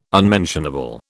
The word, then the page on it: unmentionable: British English